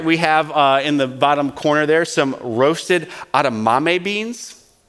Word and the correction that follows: edamame: I don't even think I'm saying that right. Adamame? Mame? – Edamame. – Edamame.